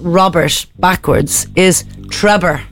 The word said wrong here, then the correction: Trebor: Trebor. – Or Trebor. – Yeah, Trebor.